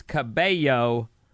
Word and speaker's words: Cabello: A double L is a Y sound. It's actually a letter in Spanish, like tortilla is tortilla, except for Vinny, who has his own pronunciation system. – Tortilla. – So Camila is Camila, single L, and her last name is Cabello as yo.